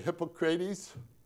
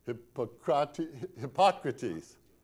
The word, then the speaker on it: Hippocrates: How do you say it? Hippocrates? Hippocrates. Got the accent in the wrong place. I like Hippocrates personally, but Hippocrates. Now that would be important to some people in this room.